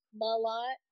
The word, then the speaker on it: Mlott: It's Mlott. But it does sound like there's a U or an A, Mlott, in there.